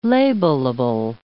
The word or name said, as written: labelable